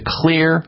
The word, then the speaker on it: Xlear: Clear Max Nasal Spray. Clear is spelled X-L-E-A-R.